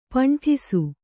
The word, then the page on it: ಪಂಠಿಸು: kannada